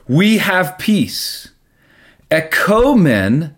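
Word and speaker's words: ekomen (ἔχωμεν): If you change it from ah to O, then now it's ekomen, not ekamen.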